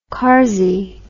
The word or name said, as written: karzey